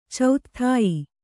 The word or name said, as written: ಚೌತ್ಥಾಯಿ